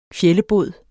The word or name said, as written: fjællebod